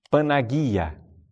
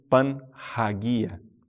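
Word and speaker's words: Panagia (Παναγία): Então, os padres da tradição oriental chamam a Mãe de Deus de a Toda Santa, Panagia. Não é? Panagia. Pan é tudo, completo, né? Hagios é santo. Panagia. Ou Panhagia, como queira pronunciar.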